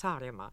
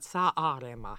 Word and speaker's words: Saaremaa: kahjuks ainult, et väga head tõlged, aga seal, kus eesti keeles on kaks A-d järjest, näiteks Saaremaa, mhm. siis mm -hmm. vene keeles kõik tõlgivad sa aarema mm, -hmm.